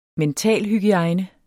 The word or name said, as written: mentalhygiejne